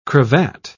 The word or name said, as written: cravat